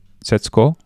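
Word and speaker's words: Setsuko: dat is de jonge Saita en haar zusje Zetsko, zeg ik dat goed zo? Ja.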